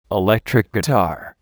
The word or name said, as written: electric guitar